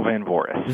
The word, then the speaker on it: Van Voorhis: That's very close. It's Daniel Van Voorhis.